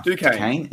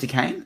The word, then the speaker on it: Duquesne: Duquesne. – Is it Duquesne? – Yeah.